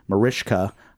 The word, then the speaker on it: Mariska: but her first name is pronounced Mariska.